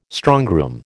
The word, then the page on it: strongroom: American English